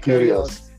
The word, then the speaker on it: Kyrgios: Kyrgios.